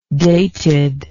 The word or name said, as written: datedness